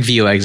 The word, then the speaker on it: Vuex: Yeah, it sounds like Vuex. It sounds like Vue and then X, yeah. All right, so now we figured out the name, so that's one good step forward. So tell us, what is Vuex?